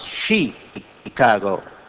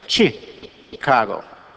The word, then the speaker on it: Chicago: Daley, once I was listening to him on television talking about the great city, our great city of Chicago, and he said, you don't pronounce it Chicago, it's chi, like chick, you know, or like chicken, Chicago.